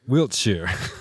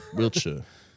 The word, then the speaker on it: Wiltshire: Wiltshire. – Wiltshire.